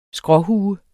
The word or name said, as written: skråhue